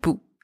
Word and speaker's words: boue: Bou spelled B-O-U-E means mud. Bou spelled B-O-U-T means tip or piece or end. So in these two words, the letter B is pronounced B and the letters O and U together are pronounced OU. And the final letters E and T are silent.